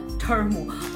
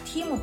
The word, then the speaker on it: Temu: 实话实说，我在收到这个 Temu 啊，这个特别要跟大家解释一下，我上次呢下单的时候那个视频呢，因为当时跟着很多美国网红念，我把这个 Temu 的名字念错，念成了 Termu。后来呢，我老公就专门到官网上查，然后纠正了我说这个是 Team Up 的意思啊。我在收到 Temu 包裹之前，我其实期待是非常低，因为我想，你就亚马逊价格 1/4，那你肯定质量也会要降级不少。